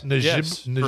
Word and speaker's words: Njigba: Jackson Smith Njigba. Did I pronounce that yes. – right? – Njigba.